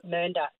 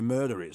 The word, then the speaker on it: Mernda: Mernda. It's M-E-R-N-D-A.